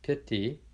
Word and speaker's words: piti: So, we now have this concept of meditative joy, piti, P I T I, piti, and the I is like a long E sound, piti.